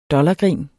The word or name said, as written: dollargrin